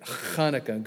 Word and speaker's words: Hanukkah: which is called Hanukkah. And you have to say it like that if you're going to be accurate. Hanukkah. Good thing you have masks on when you say Hanukkah.